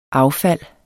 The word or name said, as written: affald